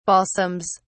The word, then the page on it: balsams: British English